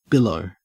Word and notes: billow: Australian English

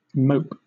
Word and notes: mope: British English

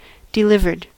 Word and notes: delivered: American English

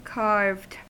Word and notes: carved: American English

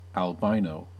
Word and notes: albino: American English

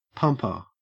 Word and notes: pumper: Australian English

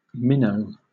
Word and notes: minnow: British English

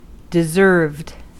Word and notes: deserved: American English

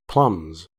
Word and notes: plumbs: American English

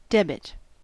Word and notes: debit: American English

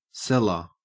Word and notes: cellar: Australian English

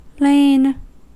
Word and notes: lain: American English

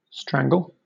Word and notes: strangle: British English

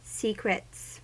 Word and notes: secrets: American English

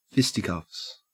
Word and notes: fisticuffs: Australian English

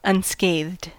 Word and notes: unscathed: American English

